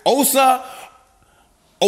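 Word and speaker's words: Osa Odighizuwa: Please welcome back friend of the show, Cowboys defensive tackle, Osa Odigizuwa. I hope I said it right, big dog. – Yo, there you go.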